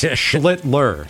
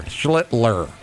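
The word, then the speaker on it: Schlittler: Aaron Judge drove in two himself as Schlitzel, right? – Are you looking at it or are you not? – I'm trying. I wrote it down phonetically. – I mean, no, you couldn't do Higashioka either, so yeah, I guess right. It's Schlitler. – Schlitler.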